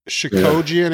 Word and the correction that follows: Chookagian: Yeah. – Isn't that how you say her name? – Again, Katlyn Chookagian. – Yeah, Chookagian.